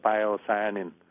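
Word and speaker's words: pyocyanin: tiếng anh đọc là piocyanin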